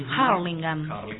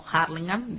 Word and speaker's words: Hurlingham: Ellos están en zona oeste, viven en Hurlingham, Hurlingham.